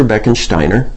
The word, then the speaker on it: Beckensteiner: His name is Inspector Beckensteiner, which happens to rhyme with minor and serves me well.